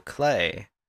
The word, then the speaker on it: Klei: Uh, Don't Starve Together was self published by Studio Klei, which rhymes with play.